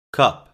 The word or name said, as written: cup